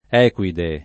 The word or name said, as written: equide